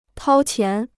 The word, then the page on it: 掏钱: tāo qián